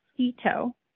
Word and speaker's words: Sito: It's pronounced Sito, like I see my toe.